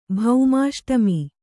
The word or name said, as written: ಭೌಮಾಷ್ಟಮಿ